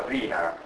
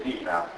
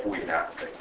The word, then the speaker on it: Wiener: Per questo alla tedesca si pronuncia Wiener. I-E. – locative nera. Alla tedesca questa roba qua si legge Vina, alla inglese Wina, però la I è sempre I, ma in tedesco è IE, Nord Wina.